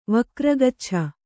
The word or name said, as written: ವಕ್ರಗಚ್ಛ